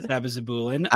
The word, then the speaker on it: sabizabulin: The FDA is meeting on their drug, Sabizabulin. I think it's pronounced. – Not bad. That was pretty good. – Sabizabulin.